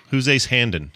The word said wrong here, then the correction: Hanlon: A lot of people say his name wrong, and I don't know why. It's something. – Hanlon? – It's burned into my head. It's Hanlon.